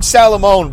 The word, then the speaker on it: Salamone: Doug Salamone, by the way, S-A-L-A-M-O-N-E.